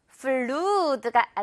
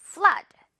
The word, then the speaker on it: flood: 그리고 flood가 아니라 어 발음으로 flood 이렇게 발음이 나요.